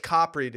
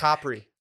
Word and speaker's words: Capri: No, it's Capri, dude. – It's sick. – Capri. That's how you say it.